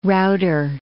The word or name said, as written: router